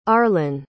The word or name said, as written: arlen